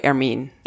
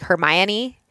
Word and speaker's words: Hermine: I think you say it the French way, Hermine. – Hermine. Okay, because I want to say it like Hermione.